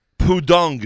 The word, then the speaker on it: Pudong: Is Pudong the right pronunciation? – Yes.